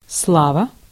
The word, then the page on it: fame: American English; /ˈfeɪm/